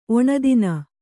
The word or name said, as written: ಒಣದಿನ